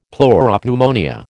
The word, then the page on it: pleuropneumonia: American English